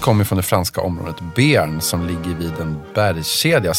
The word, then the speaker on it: Béarn: Det uttalas Béarn. Béarn.